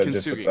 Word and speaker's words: Kintsugi: Okay, so first, and I'm going to correct you just so you know, Kintsugi. So Kintsugi. Just with the hard G.